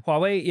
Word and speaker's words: Huawei: Is it Huawei or Huawei? – Yeah. – Huawei.